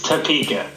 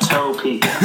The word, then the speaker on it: Topeka: Is back in Topeka, Kansas. He just finished a show over there doing his thing. – I'm going to stop you right there. It's Topeka, not Topeka.